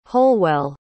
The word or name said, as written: holwell